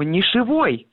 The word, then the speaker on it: нишевой: Дело в том, что в словарях дается другой вариант этого слова – «нишевой». Как бы произношение и образование, ну, то есть на основе этого прилагательного, вот такого прилагательного, какое вы говорите вы, с ударением на первом слоге и с окончанием «ый», это, ну, как бы ошибка.